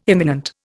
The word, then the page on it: imminent: Indian